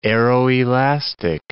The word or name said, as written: aeroelastic